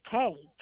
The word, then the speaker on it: Kay: My first name is Kay, K-A-Y.